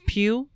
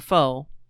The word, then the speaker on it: Pugh: We've got Florence Pugh, or Foe, from Little Women. When it's spelled P-U-G-H, you don't know what to say.